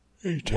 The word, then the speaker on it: ATAC: I think it's pronounced ATAC in the movie. – Oh, A-tack.